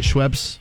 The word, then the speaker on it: Schweppes: Is that how you say it? Schweppes? – Yeah.